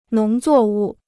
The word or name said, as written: 农作物